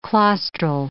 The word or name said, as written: claustral